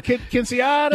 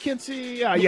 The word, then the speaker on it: Quinceañera: Quinceañera, yep. Quinceañera.